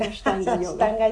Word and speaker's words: Ashtanga: ashtanga, si lo dije bien. Sí, ashtanga,